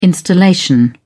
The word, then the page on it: installation: British English